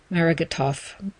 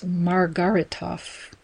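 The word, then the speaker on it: Margaritoff: And a little more detail comes from the HuffPost posting from May 26th, written by Marco Margaritov. Pardon me, that's Margaritov. Forgive my mispronunciation.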